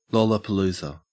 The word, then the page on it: lollapalooza: Australian English